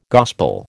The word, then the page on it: gospel: American English